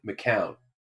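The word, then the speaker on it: McKeown: I got a his last name is spelled MC capital K E O W N McCown.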